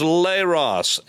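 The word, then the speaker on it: leros (λῆρος): The word is leros, L-E, long E as in they, L-E-R-O-S.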